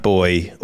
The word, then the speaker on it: buoy: No, well, it's spelled B-U-O-Y. But it's got a picture of a black boy on it. – Oh, so, boy, like in boy. The water.